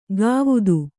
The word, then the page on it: ಗಾವುದು: gāvudu